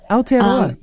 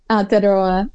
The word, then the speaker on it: Aotearoa: Because I don't know what it's like in New Zealand, in Aotearoa, but I'm sorry, my pronunciation. I've been practicing. – That's good. Aotearoa. Yeah, it's good. – Aotearoa.